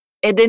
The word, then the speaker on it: ed: Hai sentito la lettera D tra le due E? ed è, nonostante la congiunzione e non abbia di solito la lettera D, dobbiamo aggiungerla quando la parola che segue comincia con una E. Lo facciamo per mantenere la pronuncia fluida.